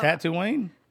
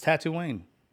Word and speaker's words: Tatooine: It's Tatooine. – That's how you say it? – It's Tatooine. – I've been saying it wrong this whole time.